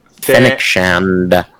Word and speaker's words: Fennec Shand: Fennec Shand.